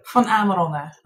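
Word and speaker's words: Van Amerongen: Van Amarongen. – Oh, okay, it's Dutch.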